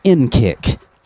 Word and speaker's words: NCCIC: NCCIC, pronounced NKIC.